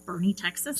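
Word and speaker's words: Boerne: And it's spelled like Born, B-O-E-R-N-E. N-E, but I guess it said Bernie, Texas.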